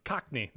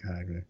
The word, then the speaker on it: Cockney: Cogni. That's it. Old Earth Cogni. – How do you spell it? – Cockney.